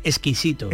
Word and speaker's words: exquisito: en cuanto a la palabra que, que, proponía Ana, exquisito, a la hora de pronunciar la X, hay que, hay que procurar no atragantarse ¿vale? Porque si uno quiere dice, ah, eh, no, no, hay que atragantar. SK, exquisito.